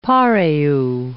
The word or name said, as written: pareu